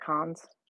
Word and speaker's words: Cannes: in the world and that's the the cons, right? That's how you pronounce it, cons.